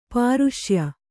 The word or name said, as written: ಪೌರುಷೇಯ